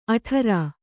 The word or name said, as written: ಅಠಾರಾ